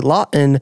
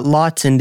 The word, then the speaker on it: Lawton: I was told that you're not supposed to pronounce the T in Lawton. You're supposed to say Lawton, not Lawton.